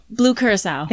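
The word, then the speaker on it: Blue Curaçao: Blue Curacao.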